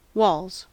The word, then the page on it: walls: American English